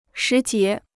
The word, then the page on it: 时节: shí jié